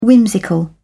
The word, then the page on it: whimsical: British English